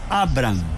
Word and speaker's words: Abraham: Abraham, sí, porque no tiene la H intermedia, no tiene la otra A, entonces la pronunciación es Abraham.